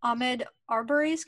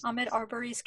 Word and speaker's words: Ahmaud Arbery: So, of course, We will have to, our shout out new for today is about Ahmed Arbery's killing. Is that how you pronounce his name? – I believe so. – Yeah, Ahmed Arbery's killing in Georgia.